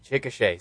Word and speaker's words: Chickasha: No, ¿lo pronuncié bien? Chickasha. – Chickasha, sí. Vamos.